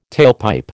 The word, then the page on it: tailpipe: American English